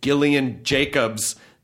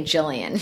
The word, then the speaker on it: Gillian Jacobs: As, like, Gillian Jacobs. That is the correct hard G, soft J. – Yeah, is the correct way.